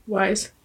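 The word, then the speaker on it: Weisz: Wise.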